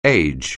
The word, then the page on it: age: anglais